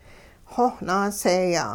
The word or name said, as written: xowh naaseeya'